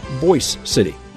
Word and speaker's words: Boise City: And while the county seat's name is spelled the same way as the capital of Idaho, it's pronounced Boise City, the namesake of a local rancher.